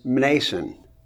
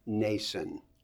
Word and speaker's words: Mnason: now I have to confess to you that for years I pronounced this Mnason. Seems to make sense, doesn't it? Except that it's a silent M, I found out later on. So I think the proper pronunciation here is Nason.